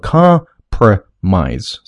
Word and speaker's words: compromise: 这里的重音在第一个音节读作 compromise 三个音